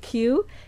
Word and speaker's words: Kyu: But that, also, we have Q, a KYU, pronounced Q, in Wynwood.